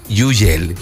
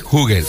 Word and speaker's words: Hugel: Ahorita tenemos Moving to the Sun, que en una entrevista le preguntaban al mismo Hugel cómo se pronunciaba su nombre, y él decía que infancia es Yugel, Yugel, infancia. Pero como uno quiera llamarle, aunque él dice que globalmente es Hugel. O si lo quieren pronunciar como infancia, Yugel.